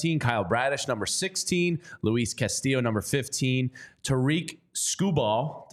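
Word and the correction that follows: Tarik: I think he's Tark. – Tark.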